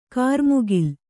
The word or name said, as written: ಕ್ರಿಮಿಕುಲ